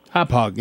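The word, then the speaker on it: Hauppauge: I spent some time researching and finally tracked down this Win TV product from, I guess you pronounce it, Hopog. – Hopog, yeah.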